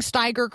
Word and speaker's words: Steiger: Am I pronouncing Steiger correctly? And go ahead. – Well, yes, you are.